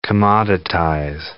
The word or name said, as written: commoditize